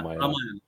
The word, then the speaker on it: Ramayana: I'm going to mispronounce that. I'm sorry. Ramayana? – Yeah, Ramayana. – Okay, good.